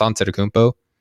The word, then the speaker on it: Antetokounmpo: That's Giannis Antetokounmpo. You know, you said that right. – Antetokounmpo? – I think so.